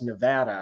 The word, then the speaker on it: Nevada: I was instructed by those who live there that it's pronounced Nevada, and I'm going to try to follow that.